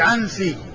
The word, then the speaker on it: العنسي: فالعيشي بالياء والشين، بالياء المثنات والشين، عيشي، وفيه عنسي بالنون والسين، وفيه عبسي بالباء والسين.